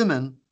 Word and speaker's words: women: كلمة وومن تكتبها W O M E N كلمة وومن امرأة مم. بينما صح. – يلفظوها يلفظوها women آه، بدل الاو يلفظ اي e، women يقولون مم.